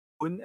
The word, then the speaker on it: Boon: So it's T, like coffee and tea. – Uh-huh. – How, as in how are you? Boon, as in good, you know, Boon.